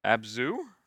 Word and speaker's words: Abzu: Abzu? Am I just pronouncing that right? – Yeah.